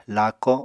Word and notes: laako7: Apatani

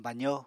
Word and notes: bazo: Apatani

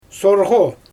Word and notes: sorɣo: Xyzyl

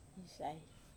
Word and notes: sai: Wamesa

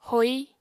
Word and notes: hoi: apatani

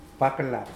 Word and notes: pwaklap: Mokilese

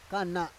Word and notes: kana: Roon

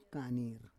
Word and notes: kanir: Roon

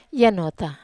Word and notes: anota: Wamesa

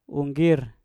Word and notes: unggir: mpur

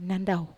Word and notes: nandau: Wamesa

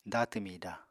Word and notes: datw mida: apatani

